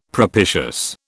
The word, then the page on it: propitious: British English